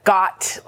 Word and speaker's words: gyat: The first one is called got, like yacht. – Pronounced like yacht with a G. – Got. – G-Y-A-T.